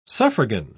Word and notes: suffragan: British English

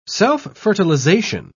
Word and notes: self-fertilization: British English

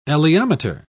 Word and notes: elaeometer: British English

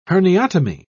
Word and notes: herniotomy: British English

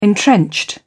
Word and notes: entrenched: British English

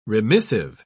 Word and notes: remissive: British English

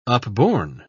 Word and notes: upborne: British English

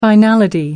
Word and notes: finality: American English

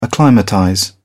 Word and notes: acclimatize: British English

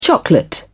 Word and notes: chocolate: British English

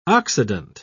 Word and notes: oxidant: British English

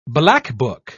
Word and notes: blackbuck: British English

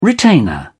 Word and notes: retainer: British English

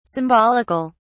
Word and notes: symbolic(al): British English

